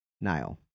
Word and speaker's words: Nihil: So when you listen to the book, you find out exactly how something's supposed to be pronounced, unless it's Markian, Martian Rowe, or Nihil, Nile.